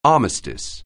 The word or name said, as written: armistice